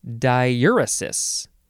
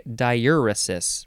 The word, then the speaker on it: diaeresis: This is the word diaeresis, and it's actually pronounced, I should fix that, diuresis. Diaeresis, the British say diuresis. I think that's close enough. It is spelled D-I-A-E-R-E-S-I-S, or you can get rid of the A near the beginning.